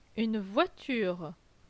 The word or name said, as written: Une voiture